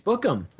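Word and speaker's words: Bukem: Tell me how to pronounce this guy's name before I butcher it. – LTJ Bookham. – Book them. All right, that's what I was going to try.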